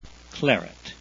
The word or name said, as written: claret